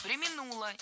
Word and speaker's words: преминула: Потом она так не преминула в воспоминаниях написать, что... – Преминула, неверно. Правильно, преминула. Ударение в этом глаголе падает на второй слог. Причем всегда, во всех формах.